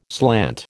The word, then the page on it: slant: American English